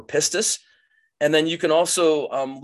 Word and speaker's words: pistis: And so you click on Strong's number, and up comes this word faith, which in the Greek is pistis. And if you don't know how to say it and you want to impress your friends, you can actually have somebody pronounce it for you. Pistis.